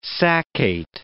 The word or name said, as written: saccate